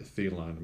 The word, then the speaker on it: Theillon: But my main page, John Thelon, my last name is spelled Theillon.